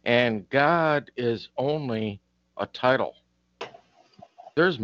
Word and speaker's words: Gad: G-O-D. And at the same time, there's also, people don't know, there's a tribe of Israel called G-A-D, which you would think is Gad, but it's pronounced as God, right?